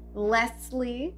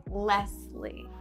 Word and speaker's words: Leslie: And I prefer that people pronounce the S, Leslie, oh.